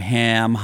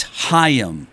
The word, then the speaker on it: Haim: Okay, I've been butchering their name nonstop, calling them Haim. Whatever. Haim. It's Haim.